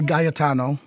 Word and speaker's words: Gaetano: So you tell me, is it Gaetano? – It's a Gaetano.